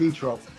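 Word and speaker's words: Zoetrope: Etrope, which is pronounced Etrope.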